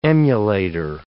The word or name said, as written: emulator